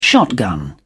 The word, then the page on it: shotgun: British English